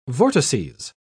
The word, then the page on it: vortices: British English